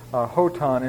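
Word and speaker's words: hoton (ὅταν): The word when is actually whenever, hoton in the Greek. And maybe just a... and you'll notice I'm pronouncing the rough breathing as more of an H sound, which may be a little bit different here.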